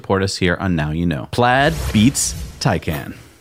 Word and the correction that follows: Taycan: I think you mean Taycan. – I can never get the name right. – You read it, you look at it with your eyes. – Yeah. – And it says Taycan. But the German in me says Taycan.